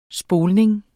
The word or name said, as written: spoling